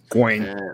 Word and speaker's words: Gawain: Gawain. – I think you are technically right on that one. I never say it right, but I know I'm supposed to say it right.